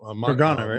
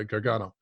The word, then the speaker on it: Gargano: I just discovered this recently, but the cave at, I always forget the how to pronounce it, but San Gargano. – Gargano, right? – Yeah, Gargano.